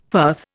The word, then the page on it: firth: Indian